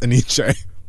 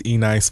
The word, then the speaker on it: Enyce: I could not pronounce Eniche. – Eniche? E-N-Y-C-E? – Yeah. – Okay. – I thought that was E-Nice for the longest motherfucking time.